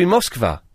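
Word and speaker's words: Moskva (Москва): We should call place names the way they're pronounced in the place. It should be Paris. It should be Newcastle. It should be Moskva.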